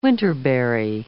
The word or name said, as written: winterberry